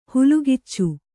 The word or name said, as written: ಹಲ್ಲುಕಚ್ಚು